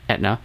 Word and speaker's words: Aetna: A-E-T-N-A, you know them? – Pronounced Aetna.